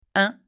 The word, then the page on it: 1: French